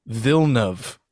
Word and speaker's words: Villeneuve: And like, his last name is not Villeneuve or Villeneuve. It's Villeneuve.